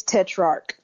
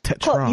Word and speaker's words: Tetrarch: And I pronounced the band name correctly, did I? – It's Tetrarch. – Tetrarch. – You were closer than a lot of people. Yes, but that's it. Tetrarch, yeah.